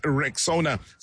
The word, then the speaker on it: Rexona: It is pronounced Rexona.